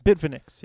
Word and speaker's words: Bitfinex: I never use a Bitfinex. – Bitfinex, yeah. – You know, Bitfinex is the one that released Tether for the first time. And there's this whole backstory that there's this conspiracy that Bitfinex was connected in the creation of Tether in controlling the whole distribution of Tether.